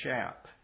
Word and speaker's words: Schaap: Jack, I think it's pronounced Schaap, S-C-H-A-A-P, Schaap.